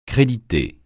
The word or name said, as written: créditer